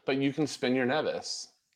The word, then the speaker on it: Neves: Yeah, John, I mean, João Neves is really good, and he probably pronounces it like Nevesh.